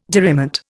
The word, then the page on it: diriment: Indian